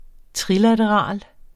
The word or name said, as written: trilateral